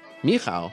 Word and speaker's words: Michał: How did you say his name again? – Michał. Michael, yeah. – Oh, that's right. Michał.